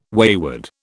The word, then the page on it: wayward: British English